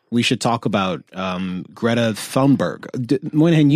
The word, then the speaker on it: Thunberg: Thunberg. – Yeah. – Thunberg. – B-E-R-G in Swedish isn't Berg. It's pronounced like it's a fruit. Berry. – Yeah. – Like you're getting a berry or something.